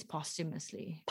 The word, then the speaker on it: posthumously: It's posthumously. But hey, what do I know? I can't pronounce anything right. – If you're American, posthumous. It's like after eating hummus.